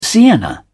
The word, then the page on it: sienna: British English